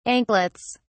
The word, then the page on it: anklets: British English